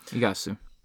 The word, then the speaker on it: Iguazu: Yeah, Iguazu.